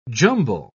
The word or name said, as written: jumble